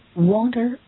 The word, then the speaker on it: wander: 如果同学们想表达环顾四周 look about, 到处走 go about, 到处跑 run about, 到处闲逛 wander about, wander, W-A-N-D-E-R, wander, 啊，不要发音发错，不是 wander, 啊， wander.